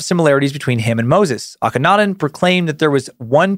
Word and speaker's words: Akhenaten: I put the pronunciation guide here, but Akhenaten. There we go. This guy. You mentioned Akhenaten and how he introduced monotheism.